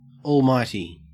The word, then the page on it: almighty: Australian English